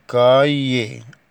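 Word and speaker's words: cahier: اون كايي، اون كايي. اون دي أداة نكرة بتيجي قدام الكلمة المذكرة. اون كايي يعني دفتر، العش لا تنطق.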